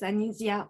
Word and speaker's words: Anisia: Anisia, right? I'm saying that right, right? I didn't want to butcher it. I was scared to death. So I didn't want to pronounce it. I'm glad you pronounced it well.